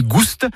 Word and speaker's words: gouste: Ni goût ni gouste, avec prononciation du S originel que l'on retrouve dans le provençal gustus, goûteux, et qui a été remplacé depuis par l'accent circonflexe dans notre orthographe moderne.